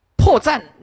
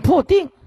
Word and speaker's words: 破绽: 罹难他念成罗难，破绽念破定，这一直念错啊啊，浪费功奴。